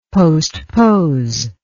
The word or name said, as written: postpose